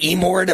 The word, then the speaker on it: Emord: Is it Emord? Am I pronouncing your last name correctly? – That's correct.